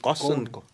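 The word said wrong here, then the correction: Ghosn: Gond. Carlos Gond was right.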